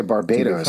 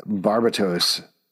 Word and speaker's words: Barbatos: I thought you were going to say Barbados or something like that. Barbatos is actually how you pronounce it.